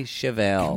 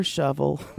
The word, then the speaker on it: Chevelle: Name is Chevelle, like a Chevy Chevelle. – Can we call her Shovel? – No, her name's Chevelle.